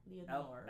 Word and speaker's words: Leonor: So it's Leonor... Did I spell O-R? Leonor. – L-E-O. – O-N-O-R.